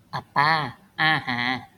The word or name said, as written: အပါအာဟာ